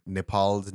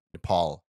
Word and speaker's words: Nepal: Uh, there's this really fun bit going on uh, on the Grade Cricketer where they apparently took Nepal's name wrong. Like they called it Nepal instead of Nepal, which is how we pronounce it.